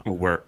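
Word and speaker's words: Uwharrie: The first major gold rush discovered in the United States took place in the Umheri Mountains. Don't know if I'm saying that right now that I know Thrash lives there. it's not an M. It's upside down M. It's a W. – Uwari.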